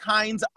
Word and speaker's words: Hinds: It's at Patrick Hines _, and my last name is spelled H-I-N-D-S _ on Instagram.